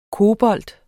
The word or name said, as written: kobolt